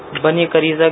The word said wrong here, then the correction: بنی قریظہ: بنی قریظہ تلفظ درست نہیں ہے، بنی قریظہ ہے، لیکن آپ کا یہ جواب غلط ہے.